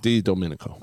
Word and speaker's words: Didomenico: Didomenico.